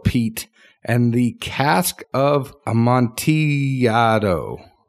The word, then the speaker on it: Amontillado: I know, but it would be Amontillado. – I like Amontillado.